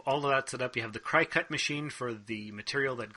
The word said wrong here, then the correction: Cricut: Cricut. It's pronounced cricket. – Oh, it's not cry cut? – No. I called it cry cut for the longest time. And people had to correct me because it's cricket.